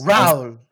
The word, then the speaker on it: Raoul: Yeah, apparently, it's been, I don't think Raoul, but it's Raoul, I guess. – Raoul. – With the little French pronunciation, I guess.